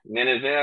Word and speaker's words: Nineveh: Nineveh, I believe, is how it's pronounced. Don't quote me on that.